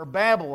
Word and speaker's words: Babel: Well, the variety of people groups and cultures were the result of God responding to man's rebellion at the Tower of Babel. Or Babel, however you want to pronounce it, revealed there in Genesis chapter 11.